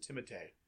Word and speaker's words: Timothée: I read that he pronounced it. He pronounces it Timothée.